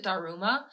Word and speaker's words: Daruma: I felt like people were mispronouncing it. And then, like, I actually heard Japanese people just pronounce it Daruma. And I was like, oh no, I've culturally appropriated this doll and I pronounce it wrong.